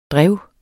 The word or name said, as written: drev